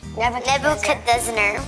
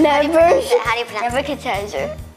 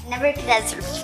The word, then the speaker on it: Nebuchadnezzar: Daniel is a noble young Jewish man taken captive to Babylon when King Nebuchadnezzar. – Oh my God. None of you know how to pronounce his name. – Nebuchadnezzar. – Okay, Nebuchadnezzar.